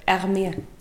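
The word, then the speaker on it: Hermès: Men man ska inte säga med S på slutet, men jag kan inte uttala Hermes på något annat sätt. Hermé.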